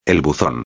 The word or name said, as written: el buzon